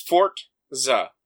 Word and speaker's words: Forza: But I guess maybe, like, the R and the Z make the RTZ, Forza. It's Fortza, is how you pronounce it.